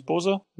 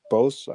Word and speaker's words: Bose: How do you pronounce it in English? Bose? – Yeah, Bose, I think.